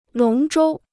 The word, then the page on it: 龙舟: lóng zhōu